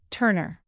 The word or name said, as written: turner